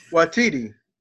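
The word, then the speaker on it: Waititi: Mr. Wakiti. No, uh, no. This man has involved. won. This man has won the Academy Award. Get his name right. Taika Watiti. Taika Watiti.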